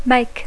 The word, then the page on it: make: English